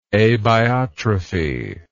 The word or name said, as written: abiotrophy